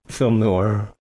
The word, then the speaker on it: film noir: Film noir.